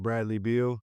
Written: Bradley Beal

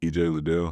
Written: EJ Liddell

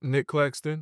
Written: Nic Claxton

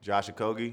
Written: Josh Okogie

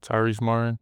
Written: Tyrese Martin

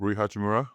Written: Rui Hachimura

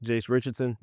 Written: Jase Richardson

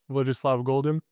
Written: Vladislav Goldin

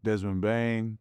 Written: Desmond Bane

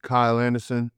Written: Kyle Anderson

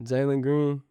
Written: Jalen Green

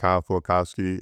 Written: Kyle Filipowski

